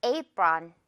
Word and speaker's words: apron: Apron. 뒷부분은요. on 발음이에요. apron, ron 아니고요. run이라고 생각하세요.